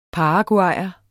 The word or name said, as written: paraguayer